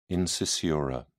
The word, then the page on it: incisura: British English